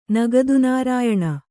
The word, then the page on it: ನಗದುನಾರಾಯಣ: kannada